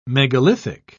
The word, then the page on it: megalithic: British English